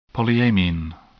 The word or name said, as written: polyamine